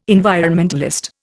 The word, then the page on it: environmentalist: Indian